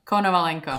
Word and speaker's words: Konovalenko: Konovalenko.